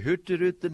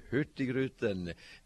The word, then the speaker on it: Hurtigruten: The Hurtigruten that you tried to pronounce. – Wait. Do that again? – The Hurtigruten, that's actually the Norwegian name, and we were known here in the U.S.